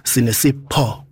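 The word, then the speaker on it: Senasipho: And I understand because in my language, the P, like you guys, PH is like, it sounds like F when you guys pronounce it. But for us, it's Po. Senasipo.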